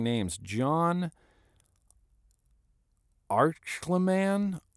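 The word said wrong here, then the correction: Ehrlichman: Archleman? A-H-R-L, Arlickman, Arlickman, Arlickman, John Arlickman, who was Richard Nixon's former chief advisor.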